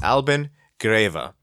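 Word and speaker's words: Albin Grewe: Albert, we previously mispronounced his name, not just once, but twice. But it's Albin Greve. We said, I first said Albin Gruy, and then it was Albin Greve.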